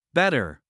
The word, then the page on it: better: /ˈbɛtər/